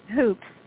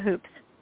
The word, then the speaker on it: Hoopes: Hoopes, Leah Hoopes.